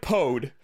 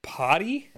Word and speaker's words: Pode: It looks like Pode, but it's pronounced potty.